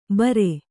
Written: ಬರ

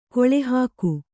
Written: ಗಾಳಿಹಾಕು

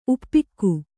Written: ಉಪ್ಪಿಕ್ಕು

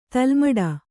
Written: ತಲೆಮುಡಿ

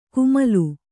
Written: ಕುಮಲು

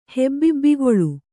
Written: ಹೆಬ್ಬಿಬ್ಬಿಗೊಳು

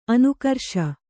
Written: ಅನುಕರಿಸು

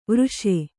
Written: ವರ್ಷ

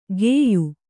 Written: ಗೆಯ್ಯು

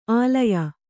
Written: ಆಳಯ

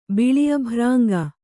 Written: ಬಿಳಿಅಭ್ರಾಂಗ

